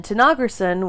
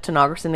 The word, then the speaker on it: Tanaghrisson: And Tenogerson, which how it's spelled is T-A-N-A-G-H-R-I-S-S-O-N,